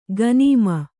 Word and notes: ಕಣ್ಣೆಮೆ: kaṇṇeme

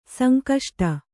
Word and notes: ಸಂಕಷ್ಟ: sankaṣṭa

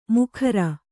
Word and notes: ಮುಖರ: mukhara

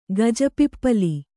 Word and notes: ಗಜಪಿಪ್ಪಲಿ: gaja pippali

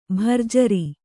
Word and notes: ಭರ್ಜರಿ: bharjari

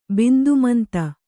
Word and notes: ಬಿಂದುಮಂತ: Kannada

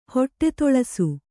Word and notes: ಹೊಟ್ಟೆತೊಳಸು: Kannada

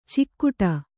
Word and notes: ಚಿಕ್ಕುಟ: Kannada

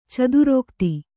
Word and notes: ಚದುರೋಕ್ತಿ: kannada

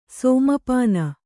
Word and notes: ಸಮಾಪನ: samāpana

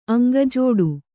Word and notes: ಅಂಗಜೋಡು: kannada

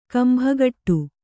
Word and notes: ಕೊಂಬುಗೊಡು: kombugoḍu

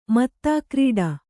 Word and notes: ಮತ್ತಾಕ್ರೀಡೆ: mattākrīḍe

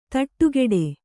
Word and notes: ಥಟ್ಟುಗೆಡು: Kannada

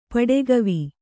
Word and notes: ಪಡೆಗವಿ: kannada

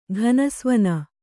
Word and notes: ಘನಸ್ವನ: kannada

